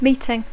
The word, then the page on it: meeting: English